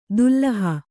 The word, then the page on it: ದುಲ್ಲಹ: Kannada